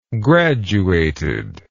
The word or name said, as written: graduated